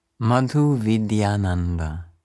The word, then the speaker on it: Madhuvidyananda: Madhu Vidyananda. Matu Vidyananda. Ja, oh, das hast du sofort so schön ausgesprochen.